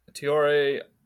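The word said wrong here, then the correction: Traoré: Adama Tiore went, how do you pronounce it? Tiore? – Adama Traore. – Traore, sorry.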